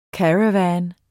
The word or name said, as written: caravan